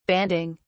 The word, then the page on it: banding: British English